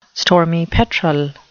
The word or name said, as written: stormy petrel